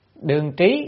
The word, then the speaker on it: đương trí: Đương trị, đương trí, cái chuyện này là phải đọc là trí ha.